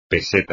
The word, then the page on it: peseta: Spanish